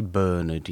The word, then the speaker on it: Bernard: And I say Bernard, yes, I know, I'm British, that's how it's pronounced.